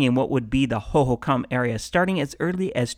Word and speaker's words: Hohokam: Now, I have heard several ways to pronounce Hohokam, and everyone seems to have their preferred way, but Hohokam seems to be the pronunciation of those who studied this ancient civilization, so that is what I'm going to go with.